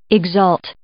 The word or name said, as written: exult